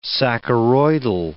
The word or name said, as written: saccharoidal